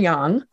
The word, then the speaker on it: yang: So in my book, I use yin and yang because it's easier to pronounce, although many people mispronounce it, yin and yang. It's yin and yang.